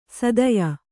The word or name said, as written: ಸೀತ್ಯ